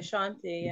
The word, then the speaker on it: Chauntai: And her name is Chauntai Samuels? – Chauntai Samuels. – Chauntai? – Yeah, Chauntai, yeah. – How do I get... Is it spelled with T-A-L at the end? – No, it's actually spelled with T-A-I.